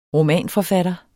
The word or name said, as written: romanforfatter